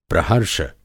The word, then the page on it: प्रहर्ष: Hindi